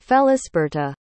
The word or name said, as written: felisberta